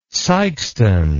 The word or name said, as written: sikeston